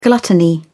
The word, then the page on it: gluttony: British English